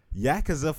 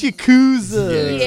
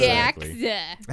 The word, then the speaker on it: Yakuza: Yakuza 5. I said it wrong on purpose because it's going to piss somebody off. – It's Yakuza. – Yakuza.